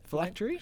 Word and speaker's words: phylactery: Philanthropy. How do you say it? – Phylactery? – Phylactery? Yeah, it's always that wrong.